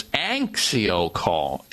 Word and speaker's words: AnxioCalm: AnxioCalm is spelled A-N-X-I-O-Calm. Kind of a play on anxiety calm, but it's AnxioCalm.